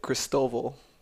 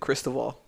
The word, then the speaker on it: Christoval: And it's everybody pronounce it Cristoval. So it's Christoval. And when I pronounce it in English, I pronounce it Cristobal. Because if you say it in Spanish, Cristobal, I was like, well, so in English it'll be Cristobal. And every time I would say it, people would be like, you mean Cristobal?